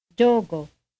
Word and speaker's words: giogo: The following is the Florentine pronunciation of the Italian word for yoke. Listen carefully and repeat. – Giogo. Giogo. Giogo. – In this pronunciation, the word for yoke contains a stressed vowel for, followed by an unstressed vowel for.